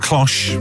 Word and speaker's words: cloche: We're going to lift the cloche.